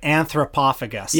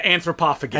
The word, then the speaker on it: Anthropophagus: Anthropophagus. – Yeah, Anthropophagus.